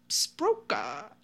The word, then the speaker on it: Sbrocca: According to Kahlo, he and Majors had been hired to murder Angelo on the orders of Romano Sproca. – Uh-huh. – S-B-R-O-C-C-A. – No, you said it right. You said it right. It's good.